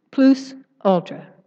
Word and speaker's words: plus ultra: After Columbus discovered the New World, Spain minted coins with the Latin slogan, "Help me with the pronunciation, plus ultra." Can someone say that better? Plus. Plus ultra or ultra? Plus ultra.